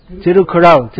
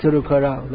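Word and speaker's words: Tirukkural: Is that how it's pronounced? Tirukovil. Tirukovil. Sorry, my Tamil pronunciation never got off the ground. Probably won't in this lifetime. So it's... No, Tirukovai is something else. Tirukural. Tirukural, that's it.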